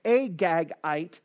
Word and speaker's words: Agagite: Haman the Agagite, that's how you say it, Agagite, the Jews' enemy.